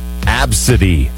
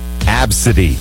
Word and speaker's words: ABCDE: It's actually pronounced Absidy. Absidy.